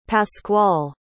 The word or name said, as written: pascual